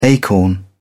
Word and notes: acorn: British English

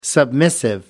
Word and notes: submissive: American English

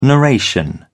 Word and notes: narration: British English

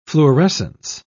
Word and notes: fluorescence: British English

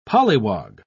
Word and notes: polliwog: British English

